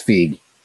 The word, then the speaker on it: Feig: I always thought it was Feig. Paul Feig. – Oh, well, yeah, it's Feig. Although he has been told that he pronounces it wrong, but that's the way his family pronounces it.